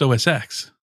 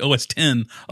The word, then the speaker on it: OS X: It's not pronounced OS X. I just like to point that out. They call you the stickler. They call me old Mr. Pedantic. – Okay. – It's actually OS 10, 11.